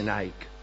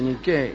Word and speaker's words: Nike: Niqué quiere decir victoria, y no se dice Nike, se dice Niqué, y por eso se llaman así las zapatillas.